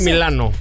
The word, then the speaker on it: Milano: Milán. Es que en italiano se dice Milano.